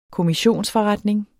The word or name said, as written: kommissionsforretning